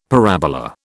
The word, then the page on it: parabola: British English